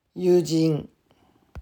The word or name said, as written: Yujin